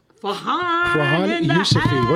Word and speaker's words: Farhan Yousfi: Farhan Yousfi.